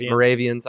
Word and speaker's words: Moravians: Is it Moravians? – Moravians. – I apologize. So we got the Moravians. I knew I put the wrong emphasis on the wrong syllable.